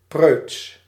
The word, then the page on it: preuts: /prøːts/